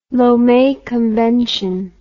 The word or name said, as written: Lome Convention